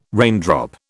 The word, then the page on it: raindrop: British English